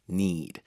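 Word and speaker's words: knead: Yeah, cats need. – 아, need. 스펠링이 K-N-E-A-D였었잖아요.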